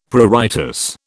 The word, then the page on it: pruritus: British English